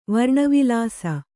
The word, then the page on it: ವರ್ಣವಿಲಾಸ: kannada